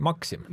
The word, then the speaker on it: Maksim: Maksimi kehastab Alex McNichol. – Või nagu filmis hääldatakse, Maksim.